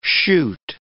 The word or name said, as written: shoot